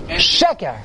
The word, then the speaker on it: sheker: Immediately, sheker. – And it makes the front page. – Sheker has the emphasis on the first syllable because that's the essence of sheker.